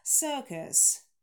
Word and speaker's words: circus: Circus – долгий шва, sir, и просто шва, кэс, кэс. Circus, circus.